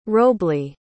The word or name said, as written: robley